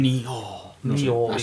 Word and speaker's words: Nioh: Nioh.